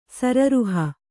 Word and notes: ಸರೋರುಹ: kannada

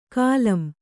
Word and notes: ಕಾಲಂ: kālam

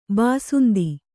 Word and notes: ಬಸಂತ: basanta

